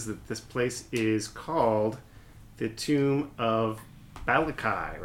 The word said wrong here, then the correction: Balke: Balke. I'm sorry. I pronounced it incorrectly.